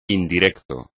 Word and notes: indirecto: espagnol

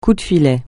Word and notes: coup de filet: French

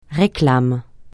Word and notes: réclame: francais

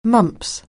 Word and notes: mumps: anglais

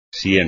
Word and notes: sien: espagnol

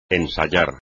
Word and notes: ensayar: espagnol

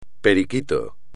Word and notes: periquito: espagnol

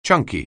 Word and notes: chunky: anglais

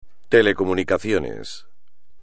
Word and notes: telecomunicaciones: espagnol

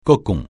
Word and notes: cocon: francais

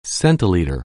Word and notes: centiliter: anglais

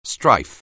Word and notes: strife: anglais

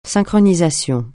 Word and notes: synchronisation: francais

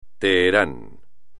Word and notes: Teherán: espagnol

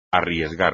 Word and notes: arriesgar: espagnol

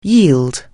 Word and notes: yield: anglais

